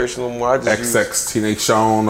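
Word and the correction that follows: XXXTentacion: XX Tentacion.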